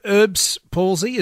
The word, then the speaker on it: Erb's palsy: Now, Peter, Erb's palsy, is that how it's pronounced? – Yes, it is, yes.